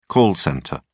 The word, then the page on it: call centre: British English